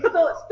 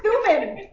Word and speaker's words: Thummim: Yumen, or Urim and Thummim. Thummim. Urim and Thummim. Now did I say it right? – I think that sounds right.